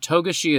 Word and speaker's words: Togashi: And then at some point in the Chimera Ant Part 1 episode, Rick says Togashi. And you can hear me start to realize in real time, oh, Togashi is definitely the right way to say that, isn't it? – Yeah.